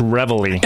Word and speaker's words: Reveille: He's involved in several different, how do you pronounce the first word? – That's Reveille.